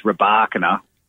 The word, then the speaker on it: Rybakina: Last year it was Rybakina, even though it's spelt Rybakina.